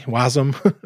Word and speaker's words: WASM: Like I know that, you know, Brett Cannon's working pretty hard on trying to figure out WebAssembly, WASM, as people like to pronounce it.